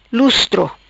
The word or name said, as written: Lustro